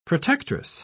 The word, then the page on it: protectress: British English